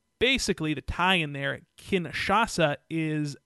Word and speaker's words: Kinshasa: What do you think of the change from the Bamae to the Kinshasa? – It's going to be hard for me to pronounce. Right? – It is a bit tricky. Kinshasa. – Kinshasa.